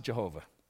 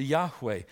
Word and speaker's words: Yahweh: God's name is Yahweh. It's not Jehovah. There is no J or V in the Hebrew alphabet. Can't pronounce it Jehovah. They pronounced it Yahweh.